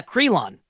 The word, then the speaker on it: Krylon: But no, here, this bottle, if you're watching the live video, it's literally filled with Krylon. Is that how you say it? The spray paint? – Yes. Yeah. Krylon. – Okay. Krylon. Whatever. – K-R-Y-L-O-N.